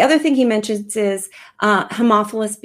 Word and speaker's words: Haemophilus: Haemophilus influenza type B.